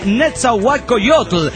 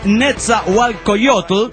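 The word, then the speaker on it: Nezahualcóyotl: Se lee Netzahualcoyotl. Es adicto al hentai, gracias. – Sé gracias, hermano, me emociono en este acto porque ya me estaban tronando de pendejo, ¿no? Ah, no sabe pronunciar Netzahualcoyotl y toda la mamada. Es Netzahualcoyotl y está bien pinche jodido.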